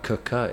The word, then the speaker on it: Quooker: Okay, so this podcast is sponsored by Cooker. It's spelled Quooker, but pronounced Cooker.